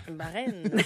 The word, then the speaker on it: Bahreïn: Bahreïn. Ah, c'est le même que ça se prononçait. On prononce pas le trémolo.